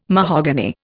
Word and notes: mahogany: Indian

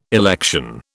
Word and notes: election: British English

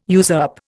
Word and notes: usurp: Indian

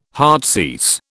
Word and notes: heartsease: British English

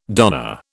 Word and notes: donna: British English

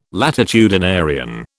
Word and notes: latitudinarian: British English

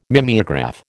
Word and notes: mimeograph: American English

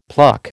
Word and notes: pluck: American English

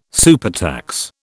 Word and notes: supertax: British English

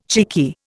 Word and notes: cheeky: Indian

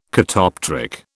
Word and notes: catoptric: British English

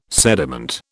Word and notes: sediment: British English

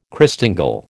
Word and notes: Christingle: American English

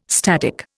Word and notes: static: Indian

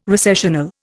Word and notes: recessional: Indian

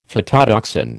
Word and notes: phytotoxin: American English

